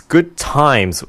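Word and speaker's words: Good Thymes: It's Good Times, and that's spelled T-H-Y-M-E-S.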